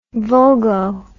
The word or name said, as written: vulgo